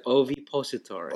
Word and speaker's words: ovipositor: patented ovipositor. Is that how you say it? – Ovipositor. – Ovipositor.